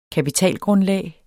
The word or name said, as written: kapitalgrundlag